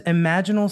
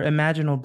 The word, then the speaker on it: imaginal: A quick note I provided George with the wrong pronunciation for a word, when he says imaginal cells or imaginal discs, it should be imaginal or imaginal. It was a little difficult to determine the correct pronunciation since it's spelled exactly like imaginal.